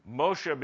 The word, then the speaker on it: Moshe: Moshe becomes Moses because the Greeks don't use SH. They convert SHs to S's. They don't pronounce SH in a normal way. The Greeks also did not like to end names with a vowel sound, and so they added an S whenever possible.